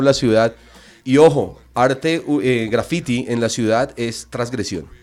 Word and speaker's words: graffiti: En Colombia nosotros lo que hicimos fue reivindicar también la epistemología y decir no, porque de hecho la pronunciación no es graffiti, la pronunciación nuestra sería graffiti y sería con esa T que tiene fuerza.